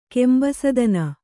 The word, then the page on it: ಕೆಂಬಸದನ: kannada